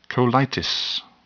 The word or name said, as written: colitis